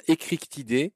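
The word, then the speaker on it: Eschrichtiidés: Échrichtidés ou écrictidés. Comment tu dis, toi Je les prononce creux, je les prononce pas che. – Oui, c'est un peu comme Christophe.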